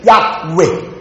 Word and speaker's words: Yahweh: 耶和华对我主说，耶和华 JYHWH，在希伯来文应当问念成 Yahweh， Yahweh 翻译成中文的时候 Jehovah，耶和华。